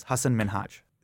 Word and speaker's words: Hasan Minhaj: So the way you would pronounce my name is Hasan Minhaj. That's like what my parents have called me. That's like the way you'd pronounce it in my cultural part of South Asia, where I'm from, right?